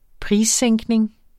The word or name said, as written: prissænkning